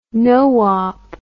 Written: noop

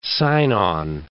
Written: sign-on

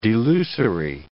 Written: delusory

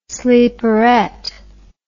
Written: sleeperette

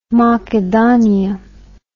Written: Makedhonia

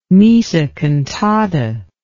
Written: missa cantata

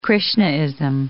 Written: Krishnaism

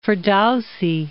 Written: Firdousi

